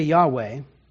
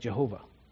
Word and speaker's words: Yahweh: Now, the reason why we say Yahweh, and we're not very sure about the pronunciation of these four letters, this YHWH that you see here in Hebrew, is because there are different vowel pointings that are used in this word that are actually used for a different word. So this is where we get mistaken pronunciations like Jehovah, because they didn't understand the vowel pointings at that time. So they weren't pronouncing the word correctly.